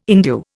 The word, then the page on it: endue: Indian